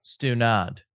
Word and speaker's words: stunad: Mm, Jamie, am I mm, saying that right? Stunad. Stunad.